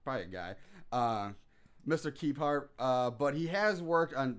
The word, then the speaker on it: Kephart: It's written by Patricia Gomez and Elsa Keppert, or I could pronounce it, could be also Kefart. – Kefart? – Her name is spelled K-E-P-H-A-R-T. – Oh, it's Kefart. – It's Kefart. – Kefart. Kefart. Yeah. – That's great. – Also directed by Elsa Kefart. Keppert. It's Kefart. We're saying Kefart.